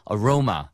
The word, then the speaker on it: aroma: 그래서 A-R-O-M-A. 향이라고 할 땐 aroma. – Aroma. – 네, 그래서 아로마는 우리끼리 쓸 때 쓰시면 되고요. 외국 사람한테 얘기할 때는 aroma처럼 하셔야 돼요.